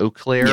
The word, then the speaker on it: Eau Claire: Am I saying that right? Eau Claire. – Yes.